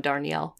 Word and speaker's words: Darnielle: And that's Devil House by John Darnielle or Darnell? – I would pronounce the I. I think John Darnielle.